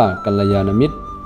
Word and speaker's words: กัลยาณมิตร: อ่านว่ากัลยาณมิตร